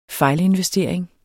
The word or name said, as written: fejlinvestering